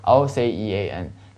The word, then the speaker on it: ocean: 那么再大点，当然就是我们说的那个大洋了，四大洋，是不是？Ocean，但是一定注意了这个词，sea 的发音是发 sh，是吧？